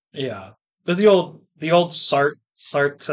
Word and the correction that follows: Sartre: Um, Sartre.